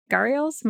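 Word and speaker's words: gharial: The otters probably, I don't know how they're working this out because they say that those crocodiles and the little gharials, am I saying it right? – I think that's right.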